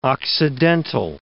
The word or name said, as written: occidental